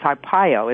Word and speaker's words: Arpaio: But my featured guest, Sheriff Joe Arpaio, I'm not pronouncing it, it's Arpaio, isn't it? – Yeah, it's a good Italian name.